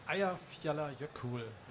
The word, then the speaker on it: Eyjafjallajökull: Ja, Eyjafjallajökull.